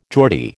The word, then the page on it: Geordie: American English